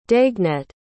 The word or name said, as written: daguenet